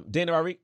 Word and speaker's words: Dania: How do you pronounce that name? – Dana Ramirez? Dana? – Is it Dana? – D-A-N-I-A? – Yeah, Ramirez.